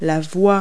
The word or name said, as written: lane